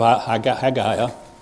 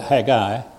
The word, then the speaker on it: Haggai: So if you weren't here, I went through a large part of Haggai. Incidentally, you can call that Haggai or Haggai, because A-I can be pronounced as an I or a guy.